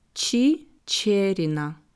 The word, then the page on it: Chicherina: slow